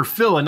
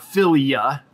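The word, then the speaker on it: Bathyphila: Bathyphilia, or phila, not philia. Oh, no wonder I'm saying it wrong. Phila is classical Greek for lover of the deep.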